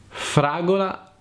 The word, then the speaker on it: fragola: Fragola non fragola.